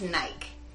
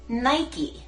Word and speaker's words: Nike: No es Nike, es Nike.